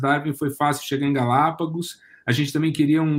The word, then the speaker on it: Galápagos: mas é um nome de quatro sílabas, Galápagos, é proparoxítono.